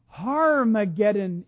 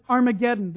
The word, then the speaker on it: Armageddon: Harmageddon in the Greek. We pronounce it Armageddon, the great battle.